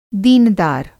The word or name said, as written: दीनदार